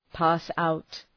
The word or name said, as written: pass out